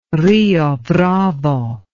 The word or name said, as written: Rio Bravo